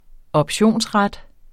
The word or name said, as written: optionsret